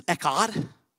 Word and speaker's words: echad: The Hebrew word for united here is an interesting word. It's echad. Echad. Everybody say echad. Say it like a good Hebrew.